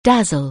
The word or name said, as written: dazzle